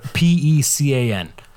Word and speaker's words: pecan: a it's a nut, but P E C A N. Uh, pecans. Pecan. All right, good.